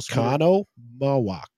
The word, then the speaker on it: Oconomowoc: You're throwing in like an extra syllable. – Oconomowoc. – Oconomowoc. Like mow the grass. Oconomowoc grass.